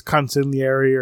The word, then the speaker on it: consigliere: Yeah, his consigliere, or whatever.